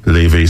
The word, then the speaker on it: Laufey: So that was Levy Spotlight. It's spelled L-A-U-F-E-Y. Levy. That's how officially said to pronounce it.